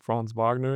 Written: Franz Wagner

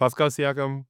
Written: Pascal Siakam

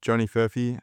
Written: Johnny Furphy

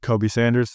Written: Kobe Sanders

